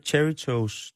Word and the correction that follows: Cheetos: Det er dem, som laver cheesy, det hedder Cheetos.